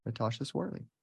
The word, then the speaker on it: Tasha Swartley: So welcome to the podcast, Tasha Swartley.